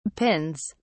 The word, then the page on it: pins: British English